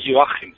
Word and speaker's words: Joachim: La gente dice aquí Joachim, pero es Joachim.